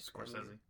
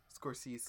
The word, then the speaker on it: Scorsese: Scorsese. – Scorsese. – Could you? It's my fucking podcast. I'll say whatever the fuck I want. – Well, and you pronounce it correctly.